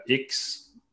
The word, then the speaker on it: X: pour commencer, ils n'ont que deux, trois, deux ou trois générations du iPhone, dont le iPhone 12, iPhone 11, euh, X ou 10, selon comment vous le prononcez, euh, qui a exactement les mêmes propriétés.